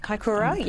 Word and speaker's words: Kaikoura: Kaikoura, yes.